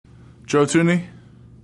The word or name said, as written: Thuney